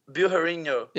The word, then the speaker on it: Bilharinho: That's your name. Jonas Bilharinho. – Bilharinho. – Yes, Bilharinho. Right? What do you think? – That's okay. That's fine by me.